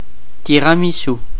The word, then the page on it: tiramisu: /ti.ʁa.mi.su/